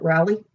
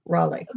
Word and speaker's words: Raleigh: Some people pronounce it Raleigh and some people pronounce it Raleigh.